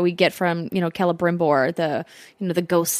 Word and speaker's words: Celebrimbor: Thank you for putting the correct emphasis on the correct syllables in Celebrimbor.